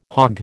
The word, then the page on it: hogg: American English